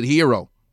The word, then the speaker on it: gyro: But the greatest, G-Y-R-O, gyro. If you're Greek, you can pronounce it hero. Geg isn't the egg. It's a gyro to me because it has a G-Y-R-O.